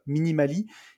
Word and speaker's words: Minimalee: Si vous voulez y aller, ça s'écrit euh, M-I-N-I-M-A-L-E-E, Minimali. Et il sort d'ailleurs, il m'a dit qu'il sortait lundi son podcast, si vous voulez aller l'écouter.